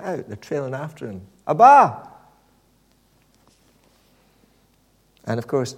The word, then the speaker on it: Abba: Now, we pronounce it Abba, okay? I think that may be the influence of a Swedish pop group. I'm not sure. But it's interesting how different people pronounce it. In Urdu, although it's spelt the same way, it's pronounced Abba.